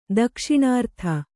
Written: ದಕ್ಷಿಣಾರ್ಥ

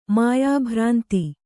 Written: ಮಾಯಾಭ್ರಾಂತಿ

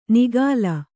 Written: ನಿಃಕಲ

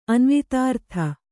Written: ಅನ್ವಿತಾರ್ಥ

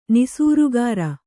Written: ನಿಸೂರುಗಾರ

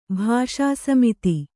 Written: ಭಾಷಾಸಮಿತಿ

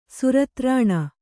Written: ಸುರತ್ರಾಣ

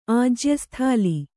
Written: ಆಜ್ಯಸ್ಥಾಲಿ

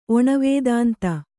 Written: ಒಣವೇದಾಂತ

